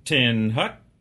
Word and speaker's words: Attention: Attention, pronounced Tin Hut, is the beginning of all military maneuvers.